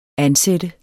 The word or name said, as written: ansat